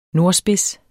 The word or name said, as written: nordspids